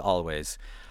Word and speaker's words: Alvvays: This is a band called Always, but they spell their name A-L-V-V-A-Y-S. So the VV stands in for the W. Yeah, I think they've had some people have been pronouncing it Always. It's Always.